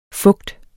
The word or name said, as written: fugt